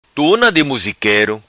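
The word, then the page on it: Tuna Di Musiquero: Macanese Patuá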